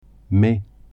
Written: mai

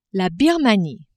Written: Birmanie

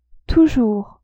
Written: toujours

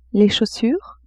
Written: chaussures

